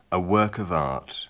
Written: a work of art

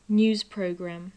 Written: news programme

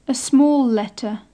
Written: a small letter

